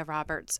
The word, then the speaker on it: Roberts: My name is Leah Roberts, and that's spelled L-E-A-H. And Roberts is traditional R-O-B-E-R-T-S.